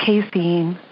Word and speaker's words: casein: Casein. I never know how to say that. – Casein, yeah.